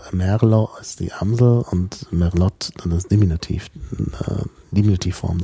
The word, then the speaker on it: Merlot: Merlot zum Beispiel, der übrigens falsch ausgesprochen wird. Da müsste man eigentlich Merlot aussprechen. Tatsächlich. – Ja. Naja, Französisch ist Merlot schon richtig, nur es ist kein französisches Wort. Es ist okzitanisch.